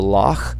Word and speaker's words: loch: Right, you go loch, so it's a loch. The CH there is like a K that you pronounce way down in your throat. It's very guttural.